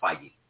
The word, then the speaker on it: Feige: And it's pronounced Feige. – Whatever. That's not how I say it.